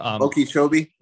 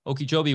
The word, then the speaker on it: Okeechobee: Okeechobee. – Yes. Okeechobee, which is close to where Jim Gale's whole operation is.